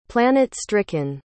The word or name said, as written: planet-stricken